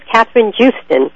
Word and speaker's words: Kathryn Joosten: I've been pronouncing her name wrong the entire show, and she's been very polite not to correct me, but it's Kathryn Joosten.